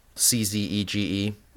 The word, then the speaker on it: Czege: I think that's how you pronounce it. Chege. Okay. Czege. – Oh. – Paul Chege. I think that sounds right. Very Czech.